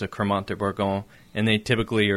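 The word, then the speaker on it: Crémant: B-I-C-H-O-T. You could pronounce it to remember it. Bichot. B-I-C-H-O-T. Bichot. And it's a Cremant, C-R-E-M-A-N-T. Think of creamy. Rosé. R-O-S-E.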